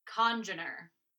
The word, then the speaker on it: congener: So we were wondering how to pronounce this word, and it is congener. – Congener. – I don't remember how else we were pronouncing it, but it's congener. Emphasis is on the first syllable.